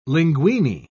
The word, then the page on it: linguini: British English